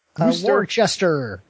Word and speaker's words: Worcester: Is it Worcester? – Worcester. – Yes, Grand Prix Boston Worcester.